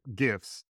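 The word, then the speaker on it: GIF: Just because I try to speak in GIFs. And apparently it is a hard G. So a correction from a previous episode, the G is hard for some reason.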